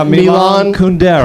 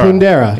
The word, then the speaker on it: Kundera: Yeah, Milan Kundera. – Kundera, yeah.